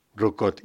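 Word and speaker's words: Roucaute: No sé decir el nombre de los autores, Denis Jenbar, Yves Rocaut, ¿cómo se dice esto? – Rucaut. – Yves Rocaut. – Denis Jenbar. Pienso que la pronunciación es esa, ya que son franceses los dos.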